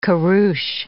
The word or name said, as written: kurus